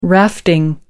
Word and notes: rafting: British English

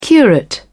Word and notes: curate: British English

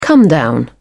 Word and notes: comedown: British English

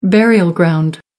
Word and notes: burial ground: American English

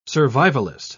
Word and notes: survivalist: British English

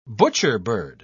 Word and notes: butcherbird: British English

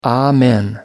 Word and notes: amen: American English

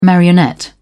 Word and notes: marionette: British English

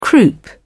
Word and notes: croup: British English

